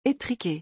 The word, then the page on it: etrique: French